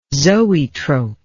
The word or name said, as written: zoetrope